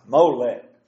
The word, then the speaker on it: Molech: And I said he did that in worship to Moloch. And that's got me all messed up ever since. I can't say it right. Molech. Molech.